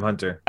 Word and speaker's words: Hunter: Hello, hi, I'm Hunter.